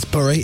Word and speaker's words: Bury: Oh, go on, big up, Barry. – Mm. – It's Bury, it's B-U-R-Y. – You've got to say it properly.